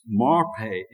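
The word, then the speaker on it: marpay (מרפא): The Hebrew word is marpay, M-A-R-P-A-Y is how you would pronounce it, marpay.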